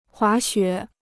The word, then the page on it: 滑雪: huá xuě